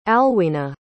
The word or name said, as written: alwina